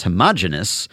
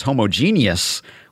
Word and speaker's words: homogeneous: So Dan says, in the latest episode, you triggered a pet peeve of mine by referring to the MVP voting as homogenous, which is technically an archaic term from biology with a one-syllable suffix. What you, of course, meant was homogeneous, which has a two-syllable suffix pronounced as in simultaneous or indeed heterogeneous.